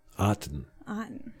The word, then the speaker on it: Otton: So this Cade Otton, I guess that's how you say it. C-A-D-E, Otten. – Cade. – Like cotton with no C. – Cade Otton. – Otton. – O-T-T-O-N.